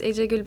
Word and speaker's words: Ecegül: Hi, my name is Echegu Bayram.